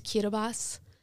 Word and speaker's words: Kiribati: There's this little country, it's pronounced Kiribati, but it's like spelled Kiribati,